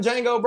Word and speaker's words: Django: It's like, in my mind, it's like, hey, Steven from the Django, bro. I'm gonna be real. Is he making it harder for niggas on that plantation? – The D is silent.